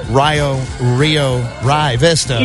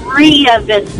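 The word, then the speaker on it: Rio Vista: Most notably, which started the ball rolling and the earth stopping on this, was the town of Rye Vista. – It's Rhea Vista. Yeah, I can't get on board with the Rye.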